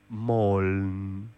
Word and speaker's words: moln: En sueco se pronuncia Moln.